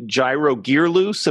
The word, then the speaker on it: Gyro Gearloose: Uh Steven Tyler calls you Gyro Gearloose. Am I pronouncing that right? Yeah, forever.